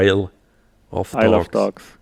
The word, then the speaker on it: Isle of Dogs: Ah, also das schreibt man ja Insel, I-S-L-E, genau. Isle of Dogs, genau.